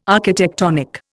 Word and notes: architectonic: Indian

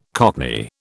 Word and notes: cockney: British English

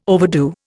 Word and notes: overdo: Indian

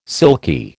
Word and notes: silky: American English

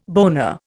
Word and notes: boner: Indian

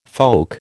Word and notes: folk: American English

